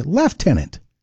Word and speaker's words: lieutenant: It's spelled C-O-L-O-N-E-L, but pronounced Colonel. And right behind it is Lieutenant. In Canada, we say Lieutenant. There's no F there.